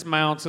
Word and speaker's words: Mounts: It's Mounts. It's like bounce Mounts. With a M on it. That's what I tell people.